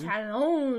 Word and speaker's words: Challonge: Challonge! – Which is spelled probably how you think it is. Challonge. – You have to pronounce it challonge. – Like a francophone, like a Canadian French person.